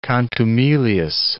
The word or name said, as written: contumelious